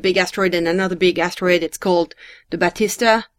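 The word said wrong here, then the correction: Baptistina: It's called the Batistina.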